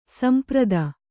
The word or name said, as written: ಸಂಪ್ರೀತ